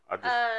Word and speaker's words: Aubrey: Is it Aubrey O'Day or Audrey? I don't know. – Aubrey. – We'll go with Aubrey.